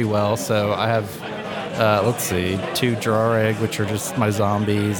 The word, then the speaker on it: Draugr: I pronounce it Draugr. – Okay. So you lose 2-1. That's how I pronounced it. – Draugr. Okay. – So there you go. If John says it's Draugr, then it's Draugr.